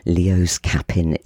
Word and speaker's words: LeosCappin: That was Lost You, and it's Leo, L-O, I'm going to get this right, hang on a minute, L-E-O-S, and Cappin, C-A-P-P-I-N. LeosCappin is how you pronounce it, so I'm told.